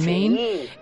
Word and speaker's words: Maine: Y ojo que lo del Maine, que se escribe Maine, se dice Maine, tiene su miga, porque no es ni mucho menos inocente todo lo que pasa, ¿verdad?